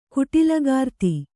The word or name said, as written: ಕುಟಿಲಗಾರ್ತಿ